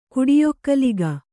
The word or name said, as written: ಕುಡಿಯೊಕ್ಕಲಿಗ